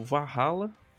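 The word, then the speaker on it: VA-11 Hall-A: Um, é o Valhalla, que é o. Ele se escreve VA, tracinho 11, HAL, H-A-L-L, tracinho A, que é uma visual novel onde você é um bartender num universo cyberpunk.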